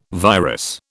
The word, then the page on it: virus: British English